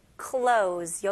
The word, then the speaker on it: clothes: cluttered with clothes. 여기서는 clothes는 z 발음으로 해주시면 돼요.